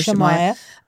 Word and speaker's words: Shemaiah: I mean, seriously, Shemaiah is spelled S-E-S-H-S-H-E-M-A-I-A-H. And we're riffing off Isaiah to get the pronunciation for Shemaiah.